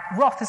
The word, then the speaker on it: wrath: Now, wrath is another old word that we don't use very much anymore. Spelt W-R-A-T-H. In English, pronounced wrath. In American, pronounced wrath. But we tend to say wrath.